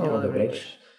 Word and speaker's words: Odebrecht: Odebrecht.